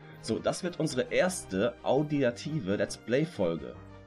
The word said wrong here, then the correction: auditiv: Habe ich das richtig gesagt, Fabian? Audiativ? Auditiv. – Ich glaube, es heißt auditiv.